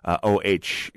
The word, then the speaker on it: Oh: O-H is how he pronounces his last name.